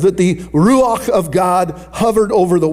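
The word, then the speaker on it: Ruach (רוּחַ): It is the Ruach of God. That's the word for spirit. It's R-U-A-H is how it's spelled in English, but it's pronounced kind of R-A-U-C-H. So there's this like sound at the end of it.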